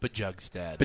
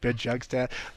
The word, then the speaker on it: Bjugstad: they just add Nick Bjugstad, who's another really good depth piece. – It's pronounced Bjugstad. – Bjugstad? – Yeah, come on. – Sorry, Nick Bjugstad.